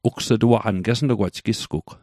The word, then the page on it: ugs'tuaqan: Mi'gmaq